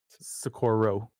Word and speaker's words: Socorro: And I know I'm going to say it wrong again, Sequoia? – Socorro. – Socorro.